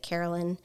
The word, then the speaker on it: Caroline: Her name is actually spelled Caroline, but she goes by she pronounces it Carolyn, and Mace is actually M-Y-S-S, in case anyone wants to look her up.